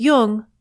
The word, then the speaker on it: Jung: Just be sure to pronounce Jung with a hard J. So with special thanks to Chiron Publications, Steve Buser, and Murray Stein, you've been listening to Speaking of Jung.